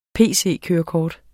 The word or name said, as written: pc-kørekort